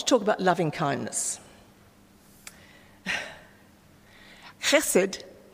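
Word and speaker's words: chesed (חסד): And it's pronounced chesed. And when I taught Hebrew, I found that many Australians have a difficulty going the ch sound. So it's loch if you're Scottish. It's definitely loch.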